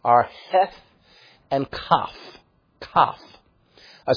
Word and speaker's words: Kaf: This consonant is often spelled with a Q, same sound though, Kaf. In fact, that K sound that you hear, this letter, Kaf, is one of three K sounds in the Hebrew language.